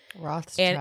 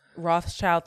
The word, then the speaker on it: Rothschild: I don't want to butcher his name, but his last name is Rothschild. – Rothschild. And it, Rothschild, thank you.